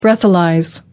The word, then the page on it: breathalyse: American English